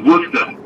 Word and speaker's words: Worcester: It's Worcester. – Worcester. All right. – Yeah. Worcester spelled like Worcester.